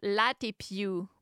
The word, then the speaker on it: Latte+: I think they pronounce it Latte Più, but it's written with a plus sign. So it's like Latte Plus.